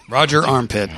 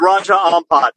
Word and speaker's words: Raja Ampat: It would be Raja Ampet. – Roger Armpit. – Okay, how do you say that? I think you know the proper... – It's Raja Ampat. – Exactly, Ampat. Not Roger Ampit.